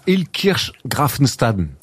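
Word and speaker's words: Illkirch-Graffenstaden: Ouais, je joue à Ilkirch Grafenstaden. – Très bien prononcé, parce que c'est pas toujours évident.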